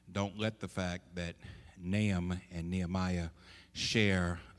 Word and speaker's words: Nahum: When we talk about Nahum, and that's the way it's pronounced, I've heard some people say Nahum, but that's not the appropriate pronunciation of the word. The proper title is Nahum, the emphasis on the A, Nahum, Nahum.